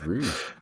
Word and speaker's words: Rouge: Is it Rouge? Rogue or Rouge? I was going to say, have we been saying it wrong at my house? – It's Rouge. – I'm pretty sure it's Rouge.